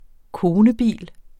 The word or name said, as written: konebil